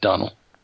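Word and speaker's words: Domhnall: No, I always feel bad about mispronouncing Brendan Gleeson's son's name. – Donnell? – Yeah, it's just Donnell.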